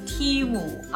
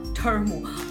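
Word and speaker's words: Temu: 实话实说，我在收到这个 Timu 啊，这个特别要跟大家解释一下。我上次呢下单的时候那个视频呢，因为当时跟着很多美国网红念，我把这个 Timu 的名字念错，念念成了 Term。后来呢，我老公就专门到官网上查，然后纠正了我说这个是 Team Up 的意思。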